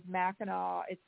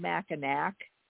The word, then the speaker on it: Mackinac: So there's, and everybody really, if they ever get a chance to go, it's so up there's Mackinac. It's spelled Mackinac, but they say Mackinac, and so there's a Mackinac, Michigan, and then there's Mackinac Island.